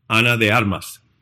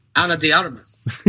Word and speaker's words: Ana de Armas: How did your people pronounce it again? – Ana de Armas. – Ana de Armas.